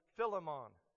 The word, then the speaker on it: Philemon: I wanted you to take note of the fact that Philemon, or if you're from the from Appalachia like me, we say Philemon. It actually sounds like filet mignon if you pronounce it correctly. Philemon.